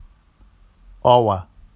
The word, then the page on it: ɑwa: Vafsi